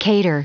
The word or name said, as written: cater